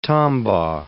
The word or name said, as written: Tombaugh